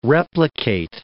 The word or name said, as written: replicate